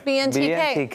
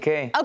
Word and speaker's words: BNTK: BNTK. – BNTK.